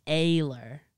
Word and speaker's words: Ehler: Oh, well, how should you say it? – My German uncle says it's like Ehler.